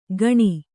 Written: ಖಣಿ

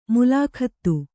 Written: ಮುಲಾಖತ್ತು

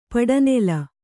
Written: ಪಡನೆಲ

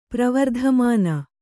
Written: ಪ್ರವರ್ಧಮಾನ

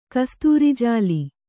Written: ಕಸ್ತೂರಿಜಾಲಿ

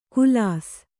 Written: ಖುಲಾಸ

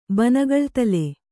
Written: ಬನಗಳ್ತಲೆ

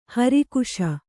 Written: ಹಿರಾಕಸ

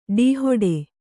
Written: ಡೀಹೊಡೆ

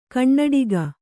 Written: ಕಣ್ಣಡಿಗ

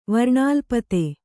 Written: ವರ್ಣಾಲ್ಪತೆ

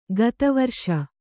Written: ಕತ್ತಿವರಸೆ

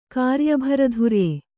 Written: ಕಾರ್ಯಭರಧುರ್ಯ

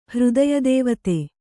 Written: ಹೃದಯದೇವತೆ

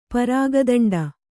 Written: ಪರಾಗದಂಡ

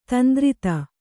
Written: ದಂತುರಿತ

